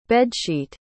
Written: bedsheet

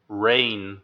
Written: reign

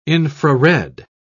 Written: infrared

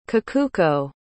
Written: kikuko

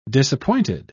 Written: disappointed

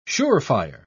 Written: surefire

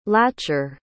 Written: latcher

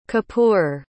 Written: kapoor

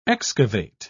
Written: excavate

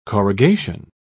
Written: corrugation

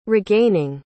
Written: regaining

